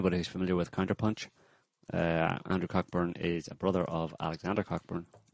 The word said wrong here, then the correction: Cockburn: I'm pronouncing the name wrong. – Coburn. – Yeah. The other one sounds like a... – Silent CK.